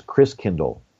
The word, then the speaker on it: Christkindl: And so the German pronunciation of Christ child is Christkindl, like kinder care, kindergarten.